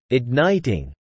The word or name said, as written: igniting